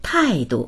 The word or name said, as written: tai4du